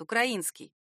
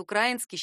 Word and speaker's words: украинский: В современном русском литературном языке правилен только один вариант — украинский. Вариант украинский считается устаревшим. Причем устарел он довольно давно.